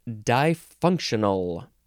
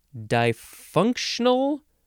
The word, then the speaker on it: difunctional: It is difunctional. Difunctional, three syllables, or difunctional, four syllables.